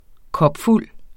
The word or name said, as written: kopfuld